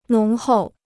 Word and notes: 浓厚: nóng hòu

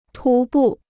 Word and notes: 徒步: tú bù

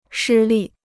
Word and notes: 失利: shī lì

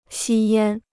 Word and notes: 吸烟: xī yān

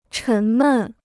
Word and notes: 沉闷: chén mèn

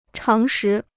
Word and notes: 常识: cháng shí

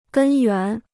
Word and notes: 根源: gēn yuán

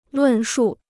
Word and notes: 论述: lùn shù

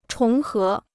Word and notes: 重合: chóng hé

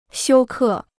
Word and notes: 休克: xiū kè

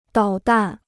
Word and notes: 导弹: dǎo dàn

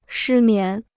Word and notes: 失眠: shī mián

